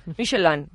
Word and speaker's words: Michelin: André y Edouard Michelin. – Muy bien. – Michelin.